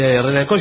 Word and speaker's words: Renancó: Dice, Renancó lleva acento en la O porque Barton dice Renancó.